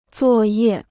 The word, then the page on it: 作业: zuò yè